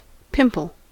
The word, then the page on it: pimple: American English